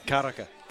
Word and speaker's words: Karaka: Like, I can't say Karaka like you. – Karaka. – Karaka. – Ka. – Ka. – Ra. – Ra. Ka. Karaka. – There you go.